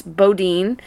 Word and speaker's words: Boudin: Boudin, which so you wouldn't think would be pronounced Boudin. That way. Right. If it was pronounced the French way, right. But it is pronounced the American way.